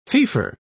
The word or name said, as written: feoffor